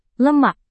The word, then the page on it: lemak: indonesian